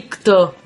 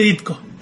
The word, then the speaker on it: Ditko: Otra noticia de cómic, pero más indie, es que aquellos cómics autopublicados por Ditko en los últimos años de su vida, lo dije bien. – Ditko. – Dije Ditko. – Sí, sí.